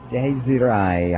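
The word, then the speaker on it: Dasri: Daisy Rai on their CD Dogma.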